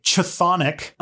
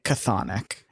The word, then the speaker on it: chthonic: And I don't, is it pronounced with the ch? Because I don't know if they say it too much, but I've been saying it Chthonic. – You know what? – The ch is a k.